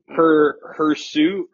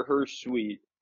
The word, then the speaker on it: hirsute: But her suit or her suite? I think it's her suit.